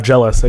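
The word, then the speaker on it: Gelus: I believe his name was Gellus. – Jealous, I think is Jealous. How to pronounce it, yeah.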